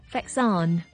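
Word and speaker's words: vaccin: 不过佢嘅读音咧就大有不同啦。疫苗嘅法文叫做 vaccine。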